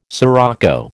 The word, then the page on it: sirocco: American English